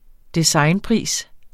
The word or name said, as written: designpris